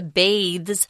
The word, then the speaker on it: bathe: Bathe has the E on it, but the noun form is just bath. So the vowel changes too. After Eliza bathes, that's that long A, and changes into new clothes, Higgins and Pickering can barely recognize her.